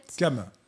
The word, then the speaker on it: Cham: Cam. – Cam. – On prononce Cam.